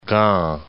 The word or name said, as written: Gand